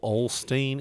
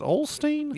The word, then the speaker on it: Osteen: Didn't I send you that video privately of the Joel Osteen? Is it Osteen? – Yes. – Is that how you say it? – Yes.